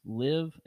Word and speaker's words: LIV: I guess it's pronounced Live, L-I-V, Golf League.